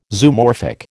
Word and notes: Zoomorphic: American English

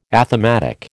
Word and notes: athematic: American English

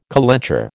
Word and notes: calenture: American English

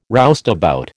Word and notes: roustabout: American English

